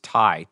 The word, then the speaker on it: Tai: It's Ty, T A I.